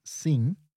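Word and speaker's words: sim: Okay, so first of all, the word for yes is sin, so it's an ing sound.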